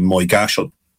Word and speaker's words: Moygashel: And I suppose the worst example of this occurred in Moygashal, which is a village in County Tyrone, which it's spelled Moygashel.